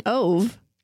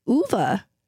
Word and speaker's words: Ove: We are reading and comparing A Man Called, we've been saying Ove, but it's not. It's Uva.